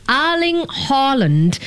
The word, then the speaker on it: Erling Haaland: So I learned that the native pronunciation of this guy's name is Erling Haaland.